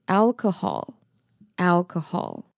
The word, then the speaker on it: alcohol: 那我们最后看一下 alcohol 这个字，它有三个音节，分成 L 可 Hall，那 AL 这个就跟我们在讲英文字母 L 的感觉是一样的 L Alcohol，然后 CO 是发 K K HOL 发 Hall Hall，所以连在一起就会是 Alcohol Alcohol。